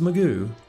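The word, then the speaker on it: McGough: Yeah, it's pronounced Magoo.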